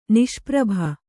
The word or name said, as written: ನಿಷ್ಪ್ರಭ